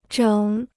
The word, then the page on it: 整: zhěng